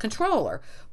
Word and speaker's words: comptroller: So it's pronounced controller. But somewhere people thought that the word actually came from the French compte, COMPTE. I didn't pronounce that correctly. I'm just doing it phonetically, which means an account. So then people started spelling controller, comptroller, even though it was pronounced controller.